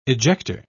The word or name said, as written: ejector